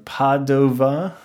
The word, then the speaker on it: Padova: Padua, or Padova, as I've heard it pronounced both ways.